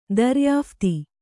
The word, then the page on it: ದರಿಯಾಫ್ತಿ: Kannada